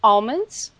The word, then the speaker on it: almonds: Almonds. – Yeah. – Almonds? – There's an L in there, right? – There's an L in there. – You pronounce the L, right? – Yeah, but I got to say something.